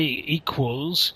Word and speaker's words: equals: So it's, when you say equals, think of a W in the middle.